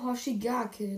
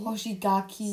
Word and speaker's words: Hoshigaki: Kisame Hoshigake, den man natürlich kennt, ne? – Hoshigaki.